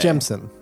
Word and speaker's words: Janssen: Janssen. Might be a soft J. – No, it could be a soft J. – It's Jemsen. – Okay. – It's probably Jemsen. A soft J when she wants it to be and a hard J when she wants it to be.